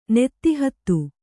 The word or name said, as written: ನೆತ್ತಿಹತ್ತು